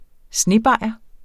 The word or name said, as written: snebajer